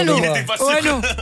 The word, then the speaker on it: Ono: Il sera d'ailleurs ce soir du côté du Ono. – Ouais, c'est ça, bien vu. – Putain, j'étais pas sûr. – Ono.